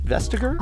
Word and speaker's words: Vestager: By the way, how do you say Vestager? Is it Vestager? – Ah, yeah, it's Vestager.